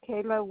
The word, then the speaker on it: Kayla: Yeah, it's Kayla Wells.